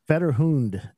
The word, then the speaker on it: Fetterhund: Federhund Racing. Is that how you pronounce it? – Federhund.